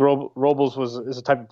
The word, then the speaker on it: Robles: I found out his her name's actually it's pronounced Robles. – Oh, is it? – Yeah, it's not Robles. I I thought it was.